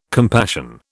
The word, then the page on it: compassion: British English